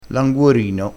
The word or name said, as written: languorino